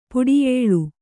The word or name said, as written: ಪಡಿಯಳೆ